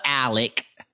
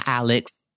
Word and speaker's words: Alec: Number one, I love Alec, because that's how you say it. Alec, yes. Not Alex.